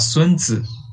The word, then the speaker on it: Sun Tzu: Só Sun que no, origi no, no original chinês a gente fala Sun Tzu.